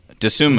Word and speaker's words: Dosunmu: Like the rookie who I really hope I don't mispronounce his name, but Ayo Dosunmu. – Dosunmu, mm-hmm.